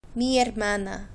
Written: mi hermana

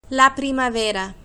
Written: la primavera

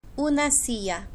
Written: una silla

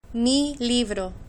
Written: mi libro

